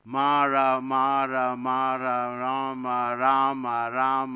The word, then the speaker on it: Rama: And Valmiki said, "What? Mama? No, no, no, Rama. Karma? No, no, no, Rama."